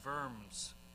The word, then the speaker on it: Worms: They called Luther to a trial before them, at what's known as the Diet of Worms. It's spelled worms, but they pronounced their W's like a V.